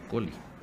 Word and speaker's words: Colli: Que se escribe Colli, pero se pronuncia Coli.